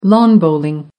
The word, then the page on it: lawn bowling: American English